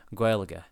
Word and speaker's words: Gaeilge: Number one, in Ireland, they refer to the language as Irish or Gaeilge, which is pronounced like, and then he spells it out, Gaeilge.